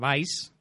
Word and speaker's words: Vice: Sí, os comento, yo propuse a Vice, que se escribe V-I-C-E,